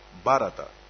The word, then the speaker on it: Бхарата: В санскрите, в этом языке, звук ха не читается. Это у нас ведь ха. Хорошо, мы говорим. Но в санскрите этот звук вообще не произносится. Поэтому Бхарата. Бхарата. Бхарат значит брат. Если вы ха не произносите, получается просто брат.